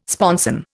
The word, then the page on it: sponson: Indian